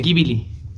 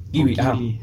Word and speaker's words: Ghibli: Entonces ella estaba viendo muchas películas de, de estudio Gilby, creo que se dice así. Ghibli. – Ghibli, ajá.